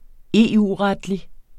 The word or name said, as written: EU-retlig